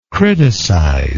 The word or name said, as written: criticizingly